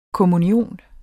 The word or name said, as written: kommunion